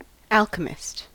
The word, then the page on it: alchemist: American English